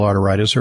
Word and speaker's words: arteritis: It's like, it sounds like I'm mispronouncing arthritis, but I'm deliberately saying arteritis because it's an itis. It's an inflammation of the arteries.